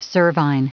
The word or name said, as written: cervine